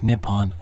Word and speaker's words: Nippon: The name for Japan in Japanese is written using the kanji and is pronounced Nippon or Neon.